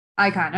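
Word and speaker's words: Icahn: Econ School of Medicine at Mount Sinai. Did I say that correctly? Is it Econ? – Icon, okay.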